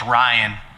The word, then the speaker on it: Rhien: And it's super, it's pretty, we don't get this a lot, but there was a guy, his name was Ryan, and he spelled it R-H-I-E-N, and people ask us that all the time, but we pronounce it like Orion, like Orion's belt, right.